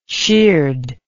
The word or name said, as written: sheared